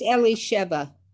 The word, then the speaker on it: Elisheva: It's it's Elisheva. Elisheva. Beautiful. Thank you. I, that's even better. I like that even better. Elisheva.